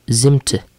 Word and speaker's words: Zimt: Zimt. Z-I-M-T.